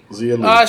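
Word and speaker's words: Xia Li: Is it Zia? X-I-A? – Zia? Yeah. – Zia Lee? – Zia Lee.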